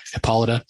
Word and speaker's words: Hippolyta: I completely butchered her name. Hippolyta. And it's exactly what I said.